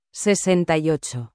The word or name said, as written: 68